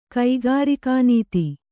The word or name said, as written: ಕೈಗಾರಿಕಾನೀತಿ